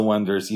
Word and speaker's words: Oneders: Okay, so it's basically about this group who calls themselves the Wonders, even though it's spelled the O-Meters. – O-Neeters. – O-Neeters. – O-N-E-D-E-R-S.